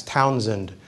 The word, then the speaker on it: Townshend: Now, it's spelled with an SH, looks like Townshend, but it's pronounced with a hard S, Townsend duties, named after Charles Townsend.